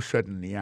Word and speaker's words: certainly: pour un mot certainly accent tombé sur premier syllabe